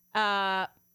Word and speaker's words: parakletos: We mentioned last week that word is, I pronounce it parakletos. Pastor Jim, our Hebrew and Greek scholar, says it is pronounced parakletos. That's it, parakletos.